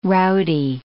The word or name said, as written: rowdy